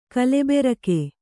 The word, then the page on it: ಗಲಬರಿಕೆ: galabarike